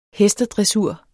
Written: hestedressur

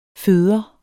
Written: føder

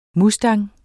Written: mustang

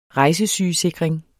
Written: rejsesygesikring

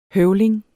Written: høvling

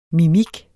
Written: mimik

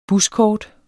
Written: buskort

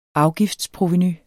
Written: afgiftsprovenu

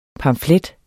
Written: pamflet

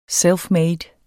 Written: selfmade

